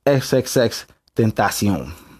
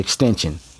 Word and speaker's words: XXXTentacion: That's how you say his name for anybody who don't know. I ain't gonna lie. When I saw his name, I thought it was extension. You feel what I'm saying? But it's XXXTentacion.